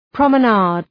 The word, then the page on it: promenade: ,prɒmə’neıd